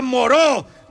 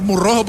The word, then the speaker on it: Moreau: Se pronuncia Moreau. ¡Doctor Moreau! – Por favor. – Un poquito de calma, por favor. Un poco de calma. – Desalojen al doctor Moreau, por favor.